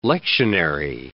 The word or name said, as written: lectionary